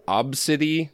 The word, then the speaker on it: Abcde: social media, and it was all because her daughter's name is Absidy. – A-B-C-D-E. – That's right. – Absidy. – They did pronounce it as Obsidy on NPR earlier, but who knows?